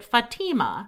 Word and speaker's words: Fatima: She did say this is a traditional Palestinian name, and she pronounced it Fatima. Maybe there are regional dialect pronunciations, but that was the one she used.